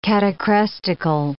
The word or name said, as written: catachrestical